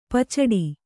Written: ಪೀಚಾಡಿ

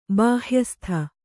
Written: ಬಾಹ್ಯಸ್ಥ

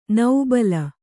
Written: ನೌಬಲ

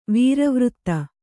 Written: ವೀರವೃತ್ತ

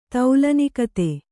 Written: ತೌಲನಿಕತೆ

